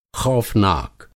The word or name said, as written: ख़ौफ़नाक